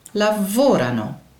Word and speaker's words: lavorano: Horen jullie ook hoe de klemtoon bij de laatste, bij loro, net even anders valt? hij is, hij is eigenlijk hetzelfde als io lavoro. Bij zij werken valt hij weer op dezelfde, namelijk loro lavorano.